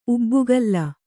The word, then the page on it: ಉಬ್ಬುಗಲ್ಲ: ubbugalla